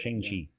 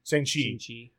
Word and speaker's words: Shang-Chi: So we got Black Widow, and they've announced that they're going to do a Shang-Li. Is that how you say it? – Shang-Chi. – Shang-Chi.